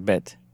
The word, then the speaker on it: bête: Yeah, B-E-T-E, but it's pronounced Bête.